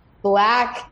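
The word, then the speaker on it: 6LACK: So up next, I'm going to play my, classic embarrassing song, East Atlanta Love Letter by Black Spelled Six Lack. It still weirds me out that that's how you pronounce it.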